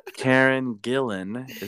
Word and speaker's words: Karen Gillan: Karen Gillan. Is that, am I saying that right? – Yeah, looks good, feels good.